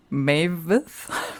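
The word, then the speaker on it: Maevyth: Da haben wir mal wieder so einen Namen, den man kaum aussprechen kann, weil sie heißt Mavith.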